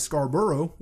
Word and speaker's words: Scarborough: But that's how you say it. It's not like Scarborough. It's Scarborough.